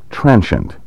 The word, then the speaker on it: transient: Since we're discussing pronunciation, I should point out that you will often hear educated speakers pronounce our keyword, transient, in various other ways, perhaps most commonly as transient, especially when the word is used as a noun to mean a homeless person, vagrant, or vagabond. Despite the popularity of the three-syllable variants, I recommend transient with two syllables, because it is the traditional American pronunciation and the one listed first in nearly all modern American dictionaries.